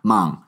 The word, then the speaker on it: Hmong: I think it's Hmong. – Hmong, yes. Oh, you're right. You're right. It is Hmong.